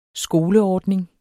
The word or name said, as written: skoleordning